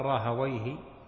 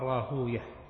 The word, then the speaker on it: راهويه: وإسحاق بن إبراهيم هو الحنظلي مولاهم المروزي المشهور عند العلماء بابن راهويه، ويقال راهويه ويقال راهوية، فالمشهور في نطق أهل الحديث راهوية زنجوية سيبوية، وأما أهل اللغة فراهويه وسيبويه وزنجويه وهكذا، وهي من ألفاظ العجم الألفاظ الفارسية التي عربت.